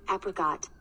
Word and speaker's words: apricot: In American English, apricot. – Apricot. – Ooh. – Apricot. Ah. It's a short A.